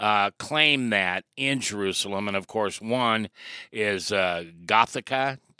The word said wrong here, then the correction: Golgotha: Did I say that right? – Golgotha. – Well, all right, I was close.